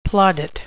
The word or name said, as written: plaudit